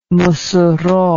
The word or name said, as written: Mossoro